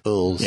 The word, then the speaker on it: Elwes: Elwes, is that how you say it? – Yeah, Elwes.